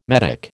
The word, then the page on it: metic: American English